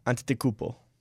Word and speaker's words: Antetokounmpo: My nephew taught me how to say this. It is Giannis Antetokounmpo. – Antetokounmpo.